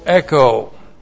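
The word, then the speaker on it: echo (ἔχω): E-C-H long O, echo.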